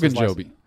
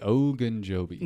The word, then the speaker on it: Ogunjobi: Ogunjobi. – Missing him in the middle. – Say it with me, Ogunjobi.